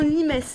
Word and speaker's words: limes: Vous voyez L-I-M-E-S, ça se prononce limes. En latin, on prononce toutes les lettres.